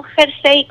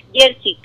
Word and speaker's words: jersey: No jersey con J, escrito tal cual, no, sino jersey, que es nuestra manera de pronunciarla.